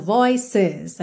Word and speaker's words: voices: Então aqui voices, é interessante reparar que esse é é pronunciado voices. A gente realmente tem duas sílabas aqui, OK?